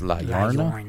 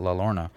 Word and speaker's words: Llorona: So next we have La Llorona, which is actually, it's two L's, so it's supposed to be pronounced La Llorona. Yeah, but people call it La Llorona.